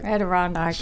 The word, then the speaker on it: Adirondack: Andorak. Oh, I can't remember how to say it. – Andorak. – I seem to remember she could not remember how to say Adirondack. – Adirondack. That's right.